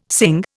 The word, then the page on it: Singh: Indian